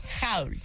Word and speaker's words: haul: Entonces, ¿cuál es la pronunciación correcta? – Howl. – Howl. Y se escribe H-A-U-L, Howl, en redes.